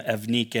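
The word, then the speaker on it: Eunike: By the way, that name Evnike is kind of a hard one to pronounce, so I just looked at the Greek, and that's how you pronounce it. Trust me on that one.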